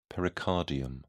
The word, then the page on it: pericardium: British English